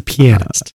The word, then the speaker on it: Pianist: Yes, The Pianist. – Right. Accent on the E, not the A.